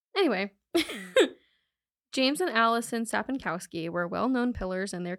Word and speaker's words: Sapinkowski: Sapinkowski. Sapinkowski family from Chapel Hill, North Carolina.